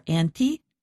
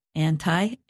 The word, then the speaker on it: anti: And you said there are three different pronunciations of anti. – Yes, it might be confusing for our listeners. They are anti, anti, and ante.